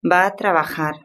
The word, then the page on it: va a trabajar: European Spanish